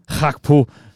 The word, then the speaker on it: Gakpo: Efternavnet starter med G, men man udtaler det med H. Cody Gakpo.